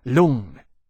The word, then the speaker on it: Lung: "How is it pronounced?" – "It is said Lung," Temeraire said, "like in my Chinese name, Lung Tian Xiang.